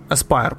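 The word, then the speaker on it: Aspyr: Е, на шоукейс е, Sony оголосила, здається, на шоукейс, що вони купили студію Aspire. Aspire. Aspire, правильно вимовляється.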